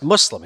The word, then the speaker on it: Muslim: it's Muslim, you know, it's a soft S, it's like pussy.